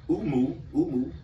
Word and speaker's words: umu: So Akkadian interpret the word, meaning of the word, which the word spirit and actually Akkadian is the word umu. That's how you say it in Akkadian.